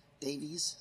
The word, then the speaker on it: Davies: This is a translation by Sioned or Sioned Davis, Davies, sorry, Oxford World Classic.